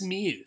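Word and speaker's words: Freiermuth: So Pat Fryermuth. Of the Steelers. – It's Meath. – Is that just how Pittsburgh people say it? – It's just pronounced Meath.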